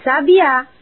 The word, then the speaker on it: sabiá: Por exemplo, sábia, com acento no A, significa inteligente. Sabia, com a sílaba tônica na segunda sílaba, é do verbo saber. E sabiá, com acento na última sílaba, é o pássaro.